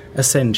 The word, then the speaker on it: Assange: Darf ich da nochmal kurz dich, aber äh, der WikiLeaks-Founder heißt übrigens Julian As Assange, oder wie man es ausspricht, Assange. Assange, ja, spricht man es aus.